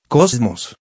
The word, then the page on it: cosmos: kɑ́zməs; コズモス